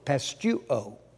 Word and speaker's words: pisteuo: The noun faith or belief translates this word, P-I-S-T-I-S, pestis. The cognate verb is spelled P-I-S-T-E-U-O, pestuo.